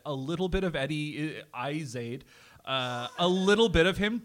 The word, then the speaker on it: Izzard: And it's actually not Izzard, it's Izzard. – He, like me, is constantly explaining the subtle mispronunciation. – It is very subtle. It's the emphasis on the first half, the first syllable, but it's the second syllable is pronounced ard, not erd.